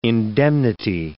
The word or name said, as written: indemnity